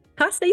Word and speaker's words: Costa: Costa, you said it right.